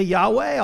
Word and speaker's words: Yahweh: And sometimes we might attempt to pronounce God's name, put in some vowels. Okay, we'll say Yahweh, although the Jews didn't believe God permitted us to say his name.